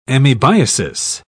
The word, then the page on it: amoebiasis: British English